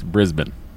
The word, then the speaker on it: Brisbane: Andy, if you're going to do the Australian accent, it's Brisbane. – Brisbane.